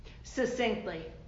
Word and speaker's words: succinct: It's like it's a hard C. The first C is hard. – It's two C. – Succinct. – S-U-C-C.